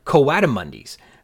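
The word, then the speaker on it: coatimundis: which I called Cotamundis. But I was not pronouncing them correctly. They don't sound like something you wear in the winter, like a Cotamundi, but instead like Croatia. They are Coatamundis.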